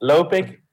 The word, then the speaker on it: Lopik: So how's it actually pronounced? – Lopik and Benchop.